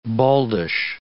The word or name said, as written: baldish